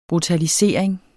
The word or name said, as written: brutalisering